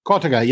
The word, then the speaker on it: cotica: Cotica? – Cotica. Yeah. It's Cotica. It's got a couple different pronunciations depending where on Italy you are,